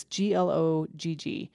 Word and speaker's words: glögg: I don't know how to pronounce it. It's glögg, a Swedish.